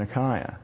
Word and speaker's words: Achaia: Achaia. I say Achaia because I've got a commitment to Erasmian pronunciation. I don't know how to say that word in Koine Greek.